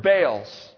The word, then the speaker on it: Baal: And when you see in the scriptures the letters B-A-A-L, it's pronounced Baal.